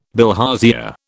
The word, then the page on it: bilharzia: British English